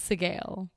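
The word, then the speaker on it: Sgaeyl: It's pronounced like scale, but with a G. So it's Sigail. It's just scale.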